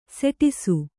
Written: ಸುಡಿಸು

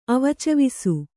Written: ಅವಚವಿಸು

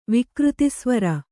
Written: ವಿಕೃತಿಸ್ವರ

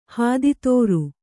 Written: ಹಾದಿತೋರು

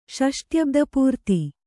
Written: ಷಷ್ಟ್ಯಬ್ದಪೂರ್ತಿ

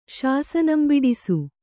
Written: ಶಾಸನಂಬಿಡಿಸು

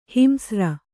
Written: ಹಿಂಸ್ರ

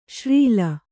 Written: ಶ್ರೀಲ

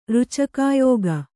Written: ರುಚಕಾಯೋಗ

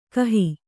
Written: ಕಾಹಿ

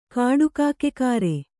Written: ಕಾಡುಕಾಕೆಕಾರೆ

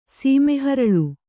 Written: ಸೀಮೆಹರಳು

